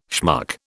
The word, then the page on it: schmuck: British English